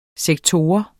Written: sektor

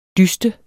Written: dyste

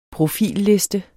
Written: profilliste

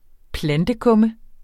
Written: plantekumme

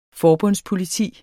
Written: forbundspoliti

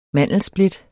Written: mandelsplit

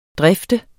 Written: drifte

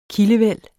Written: kildevæld

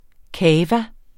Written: cava

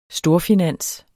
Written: storfinans

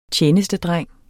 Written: tjenestedreng

